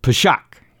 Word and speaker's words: Pesach: The first one in the spring, and of course, I can never pronounce Hebrew correctly, but it's pronounced something like Pesach.